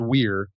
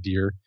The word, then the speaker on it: vir: And the word vir, it's pronounced weir, but you can say vir.